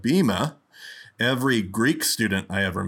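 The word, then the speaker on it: bimah: I do know that today you'll hear them pronounce the platform that we name our podcast after, the Bima.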